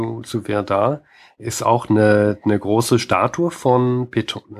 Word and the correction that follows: Pétain: Äh, Pétain.